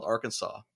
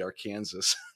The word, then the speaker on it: Arkansas: It's spelled Arkansas, but they don't call it the Arkansas River. They call it the Arkansas.